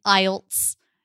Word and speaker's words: IELTS: So you're saying IELTS. You're saying the acronym, you're like making all of the sounds into an actual word.